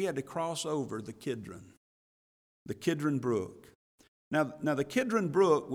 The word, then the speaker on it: Kidron: We sang the song earlier about the brook Kidron, or Cedron, depending on how you pronounce it. In the Hebrew, it's Kidron, K-I-D-R-O-N.